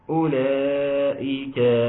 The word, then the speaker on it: أولئك: اولئك. اختنا محبه الحبيب بتقول اظهار شفوي، اظهار شفوي، اولئك ما فيها اظهار اختنا. نعم حرف لا ينطق، حرف لا ينطق.